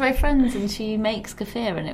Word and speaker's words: kefir: So yeah, she drinks kefir. I always say it wrong. – I really want to start drinking kefir, but it's kefir.